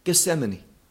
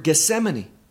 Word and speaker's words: Gethsemane: Well, even though this is Greek, this word Gethsemane comes from two Hebrew words, just translated into to the Greek language, but pronounced very similar to the Hebrew origin.